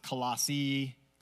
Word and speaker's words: Colossae: And by the way, some people pronounce it Colossae, you can say it either way.